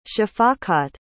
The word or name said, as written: shafaqat